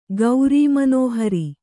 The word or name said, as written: ಗೌರೀಮನೋಹರಿ